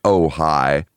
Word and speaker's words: Ojai: That album is called Ojai, and it's pronounced Ojai.